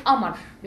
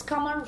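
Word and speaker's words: Kamar (قمر): We call her Amar because we don't pronounce ka in Egyptian Arabic. So it's Kamar, which means moon. And we say Amar in Egyptian.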